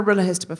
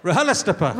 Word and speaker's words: RHLSTPGF: Are you more of a guilty feminist fan or a RHLSTPGF fan? – Yeah.